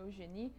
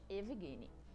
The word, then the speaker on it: Evgeny: Apesar da ânsia da gente ter de chamar ele de Eugênio, com esse vizinho que parece um U no meio do caminho, Eugeni, a pronúncia do nome desse bielorrusso é mesmo com som de EV mesmo, gente, Evgeny.